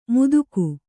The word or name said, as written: ಮುದುಕು